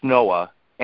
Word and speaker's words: NOAA: is clearly expressed in the N-O-A-A, pronounced Noah, animated presentation in the case of the wreck of the America.